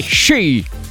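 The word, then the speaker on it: shi (四): perché si pronuncia, attenzione, shi, un po' come gli sci, quelli da mettere ai piedi, ma scritto SHI, e questa pronuncia, che spero di aver detto abbastanza bene nonostante non sappia il giapponese, cosa significa sci?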